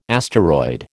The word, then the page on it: asteroid: American English